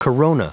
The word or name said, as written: corona